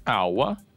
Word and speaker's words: hour: Оно начинается с согласной «h», но читается «hour», и поэтому... «an hour». Да.